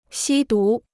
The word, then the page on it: 吸毒: xī dú